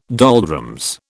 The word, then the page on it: doldrums: British English